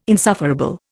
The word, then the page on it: insufferable: Indian